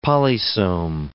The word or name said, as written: polysome